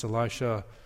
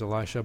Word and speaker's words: Elisha: In fact, lately I've heard people pronounce Elisha no longer as Elisha, but as Elisha. Elisha. That's actually the bit closer pronunciation to the Hebrew.